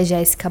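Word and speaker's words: Géssica: E para quem quiser me achar nas redes sociais, é Jéssica Borges, Jéssica se escreve com G, ou arroba Jéssica com G e tudo junto, tá bom?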